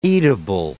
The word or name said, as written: eatable